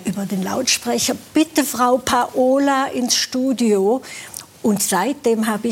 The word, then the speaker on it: Paola: und jetzt frage ich Sie aber, wie spricht man Sie richtig aus? Denn Sie werden ein Leben lang schon mit Paola angesprochen. Ich kenne die Aussprache anders. – Sie auch. – Das heißt, ja. Zu Hause hat man zu Ihnen Paola gesagt.